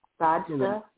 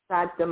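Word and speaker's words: Sajdah: Sajda. – Huh? – Sajda. My first name is Sajda.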